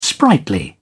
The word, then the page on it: sprightly: British English